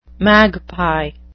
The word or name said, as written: magpie